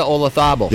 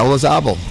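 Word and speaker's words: Olazábal: Jose Maria Olazabal. – Yeah, Olazabal.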